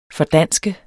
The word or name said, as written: FORDANSK